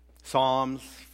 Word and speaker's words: Psalms: Psalms, for those of you who don't pronounce the P there.